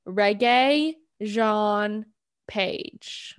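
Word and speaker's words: Regé-Jean Page: His first name is pronounced like a popular genre of music. Reggae. Yeah. – Re- Regine. What the, you just added like 20 different letters? Je- Jean is pronounced like John, but with a French accent. His last name is simply pronounced as Page.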